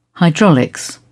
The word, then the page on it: hydraulics: British English